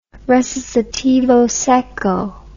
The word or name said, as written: recitativo secco